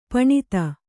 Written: ಪಣತಿ

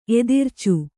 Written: ಎದಿರ್ಚು

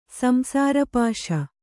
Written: ಸಂಸಾರಪಾಶ

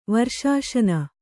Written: ವರ್ಷಾಶನ

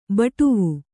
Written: ಬಾಡವ

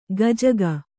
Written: ಗಜಗ

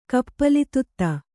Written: ಕಪ್ಪಲಿತುತ್ತ

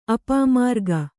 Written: ಅಪಮಾರ್ಗ

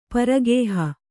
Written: ಪರಗೇಹ

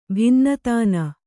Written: ಭಿನ್ನತಾನ

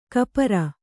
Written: ಕಪರ

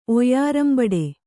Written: ಒಯ್ಯರಂಬಡೆ

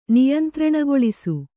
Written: ನಿಯಂತ್ರಣಗೊಳಿಸು

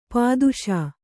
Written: ಪೊದೆಸು

